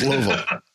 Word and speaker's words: Louisville: I made the mistake of pronouncing Louisville. – No, Louisville. – Yeah. Like, hey, you got to put some marbles in your mouth and say Louisville. – It's Louisville.